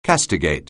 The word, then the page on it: castigate: anglais